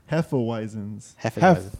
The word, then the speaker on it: Hefeweizen: So let me help you with that. – Yeah. – Hefeweizens. There is no N. There is no R.